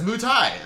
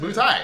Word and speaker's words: Muay Thai: And, like, so I legitimately thought that's how it was pronounced for years, like, as a young boy. You know, it's like, oh, he does Muay Thai, and then, like, I don't know why the AY is silent, you know, it's just Muay Thai, and then, yeah, then realized that wasn't the case.